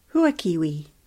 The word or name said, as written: Huakiwi